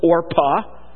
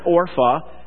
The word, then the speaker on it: Orpah: The name of the one was Orpah. And not Orpha. I hear people pronounce that Orpha, and I've done that myself. It says Orpah there, but probably Orpha is the same name.